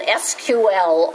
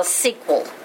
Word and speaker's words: SQL: Okay, so behind the scenes, it's going to be using SQL or SQL, which is spelled S-E-Q-U-E-L, I think. About half the people in the world called it SQL, and about half the people in the world call it SQL.